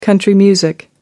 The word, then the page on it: country music: American English